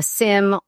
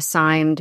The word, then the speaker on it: SIEM: a SIM or a SIEM, depending on how you pronounce it. I've heard it both ways.